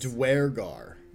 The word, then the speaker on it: Duergar: It's like D-U-E-R-G-A-R. – Durgar, right? – Durgar. – Yeah, D-U-E-R-G-R. – G-A-R. – Yeah, G-A-R.